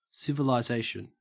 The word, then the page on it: civilisation: Australian English